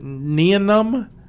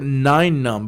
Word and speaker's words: Nien Nunb: Named uh, two ways to pronounce it. I have always heard it Nien Nunb and then I've also heard it nine num because there's a ten num too.